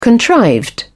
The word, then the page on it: contrived: British English